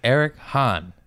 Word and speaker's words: Eric Hahn: This is Eric Hahn.